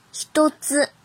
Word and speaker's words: 一つ: 有时候还读ひとつ、ひとつ、ひとつ。